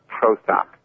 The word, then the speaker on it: Pratap: It's actually pronounced, it's not pronounced with spells, which makes it a little complicated. It's pronounced Pratap, and the last name is Chatterjee, but yeah, sorry about that.